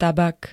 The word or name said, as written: tabak